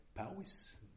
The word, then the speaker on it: Powys: Is that how you pronounce it in Welsh? Powys? Mm-hmm.